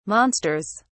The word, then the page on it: monsters: British English